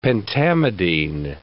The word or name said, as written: pentamidine